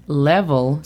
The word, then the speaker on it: level: La pronunciación fonética es M-E-I-L-B-A-X. – Mailbox. – Muy bien. Después tenemos la palabra level. Level significa nivel. La pronunciación fonética es L-E-V-O-L.